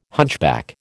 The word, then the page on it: hunchback: American English